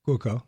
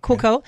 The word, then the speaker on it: Cuoco: Kaylee Cuoco? – Cuoco. – Cuoco yeah.